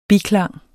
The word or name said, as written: biklang